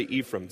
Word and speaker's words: Ephraim: Well, if you're Jewish, you say it this way. If you're Greek, you say it this way. I'm like, whatever, whatever. How do the Americans say it? So that's what I went with, all right? Americans say Ephraim.